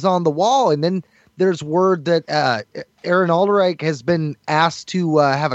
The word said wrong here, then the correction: Alden Ehrenreich: I'm butchering his name, man. Alden Ehrenreich? – Yep, there you go.